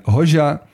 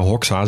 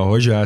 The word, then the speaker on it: Hoxha: Voor de mensen die Hoja niet kennen, dat schrijf je dus H-O-X-H-A. Dus je zou Hoxa zeggen, maar Hoja is dus volgens mij wil je het uitspreken.